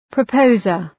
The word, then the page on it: proposer: prə’pəʋzər